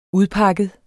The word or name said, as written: udpakket